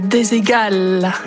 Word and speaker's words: Desigual: Coucou Samy et Lou, c'est Desigual. C'est espagnol.